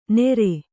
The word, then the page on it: ನೆರೆ: nere